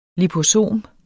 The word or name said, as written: liposom